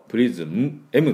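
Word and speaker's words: prison: で、 N の時、口を閉じないようにね、プリズンって口を閉じちゃうと、プリズン、M って言ってるかと思いますので、プリズン、唇をくっつけない。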